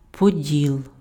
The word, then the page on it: Поділ: [poˈd⁽ʲ⁾il]; Ukrainian